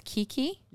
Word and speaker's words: Keke: Kiki. – This is Kiki? – Yeah. – Is it just Kiki, like Kiki Sambar? – Yeah, K-E-K-E, Kiki Liqueur, which is a key lime cream liqueur.